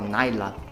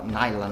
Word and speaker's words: Islay: que a pronúncia fala Naila, né, mas escreve Islay, mas fala Naila, né?